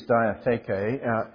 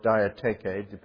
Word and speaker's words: diatheke: The word that's used here in the Greek is diatheke, or diatheke, depending upon one's pronunciation,